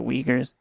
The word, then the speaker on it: Uyghurs: The Uyghurs, it sounds like Wiggers, and you're not supposed to say that.